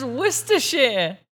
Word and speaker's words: Worcestershire: It's Worcestershire. Like the sauce. – Worcestershire. – I love how the caption says, ah, Worcestershire, the infamous sauce that no one knows how to pronounce.